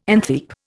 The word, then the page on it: antheap: Indian